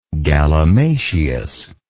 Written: galimatias

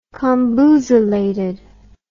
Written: comboozelated

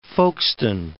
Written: Folkestone

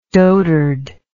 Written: dotardly

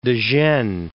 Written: De Gennes

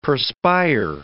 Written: perspire

